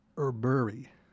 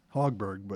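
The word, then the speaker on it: Högberg: And open up the last set, Swedish-based artist, Anna Erberg. It's pronounced H-O-G-B-E-R-G, but it's pronounced, it's spelled that way, but Hogberg, but I've been calling her Hogberg for the last couple semesters, and I've got the name wrong.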